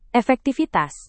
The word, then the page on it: efektivitas: indonesian